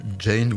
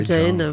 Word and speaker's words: Gein: donc qui s'appelait en fait Ed Jean, je sais pas trop comment on prononce son nom. – Oui, c'est Edward, je crois. Jane, ouais. – Jane, voilà. Hein. Ça s'écrit G-E-I-N, voilà, pour ceux qui savent bien parler anglais.